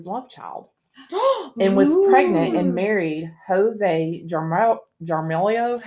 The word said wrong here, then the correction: Jaramillo: How do you say this, Tessa? You know I'm not good with names, y'all. – Oh, where is your finger? – The middle finger. Yeah. – Jaramillo? – Jaramillo. Yeah.